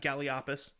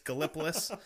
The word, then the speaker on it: Gallipolis: It's not Galliopus. It's Gallipolis, Ohio, which is just, it's actually that next town across the Silver Bridge that collapsed from Point uh-huh.